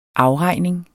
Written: AFREGNINGERNE

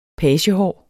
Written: pagehår

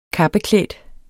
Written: kappeklædt

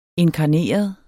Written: inkarneret

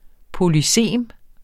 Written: polysem

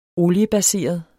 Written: oliebaseret

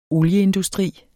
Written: olieindustri

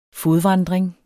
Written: fodvandring

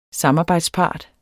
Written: samarbejdspart

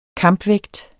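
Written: kampvægt

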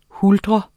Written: huldre

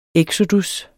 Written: eksodus

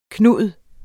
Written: Knud

